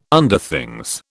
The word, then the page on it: Underthings: British English